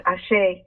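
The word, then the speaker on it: Àṣẹ: And after the name, they pronounce a word. It's pronounced Ashe.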